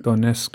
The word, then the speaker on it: Donetsk: Don Donetsk. – Donetsk ähm, 500 Bodybags an die Ukraine gespendet hat. Ähm, Donetsk. Donetsk. Ja. Okay. Gut, nochmal. Also die Bestatterin Karen Besch, die zusammen mit der Gesellschaft Bochum Donetsk 500 Bodybags an die Ukraine gespendet hat.